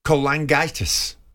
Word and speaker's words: cholangitis: You'd been diagnosed with uh, a serious liver complaint, um, primary sclerosing cholangitis. – Yeah, you pronounced that very well.